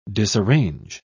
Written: disarrange

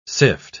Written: sift

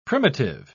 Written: primitive

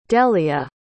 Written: dellia

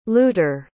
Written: luter

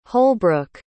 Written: holbrook

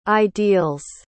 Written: ideals

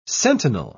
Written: sentinel

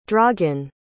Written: dragan

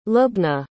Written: lubna

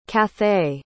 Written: cathay

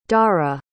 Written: dara

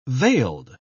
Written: veiled

